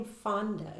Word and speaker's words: Fonda: Tell me about your name, Fonda. Is it that how it's pronounced, Fonda? – Yes, Fonda, like Jane Fonda.